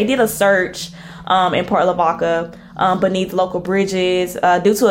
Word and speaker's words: Lavaca: I think I'm saying that right. Lavaca. I wanted to say Lavatica so bad.